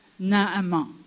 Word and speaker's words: Naaman: Naaman, Naman, pasteur, comment est-ce qu'on prononce son nom en, français Naaman, ok.